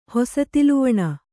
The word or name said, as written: ಹೊಸತಿಲುವಣ